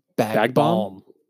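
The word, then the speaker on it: Bag Balm: I give that bitch bag bomb. She'd be on her way. – What? – Bag bomb. – Bag bomb. – There's an L in it. – There is, but you don't pronounce it.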